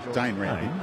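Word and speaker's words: Dane Rampe: Was that Dan? – Dane Rampy. A little silent E there at one stage.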